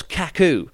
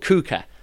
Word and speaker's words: Kuka: They call themselves Kaku, but they're not called Kaku, they're called Kuka. Uh, like really, really basic mispronunciation, but Kaku sounds crap, and Kuka actually sounds quite cool and it's really mixed up.